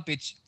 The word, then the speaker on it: Birch: Hab ich Bitch gesagt? – Ja. – Tora Birch, sorry. Birch. Tora Bird? Bird? Coming? Nee, was jetzt? Bird, Bitch. – Birch oder also ein komisches I ausgesprochen.